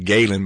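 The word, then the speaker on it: Ghalen: It's called Galen. By the way, that's spelled G-H-A-L-E-N. G-H-A-L-E-N, if you're curious.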